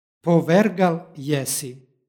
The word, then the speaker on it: povergal jesi: stihu pročitali smo povergal jesi. Pošto sonant stoji na kraju reči, ispred njega je suglasnik, dodaje se u izgovoru nepostojano a radi lakšeg izgovora.